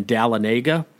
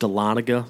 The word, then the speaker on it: Dahlonega: Back in Atlanta, you could always tell the new weather guy on the news because he would say it's raining in Dahlonega. It's actually pronounced Dahlonega. It's a little mountain town in North Georgia. But we always got a kick when the new guy pronounced it Dahlonega.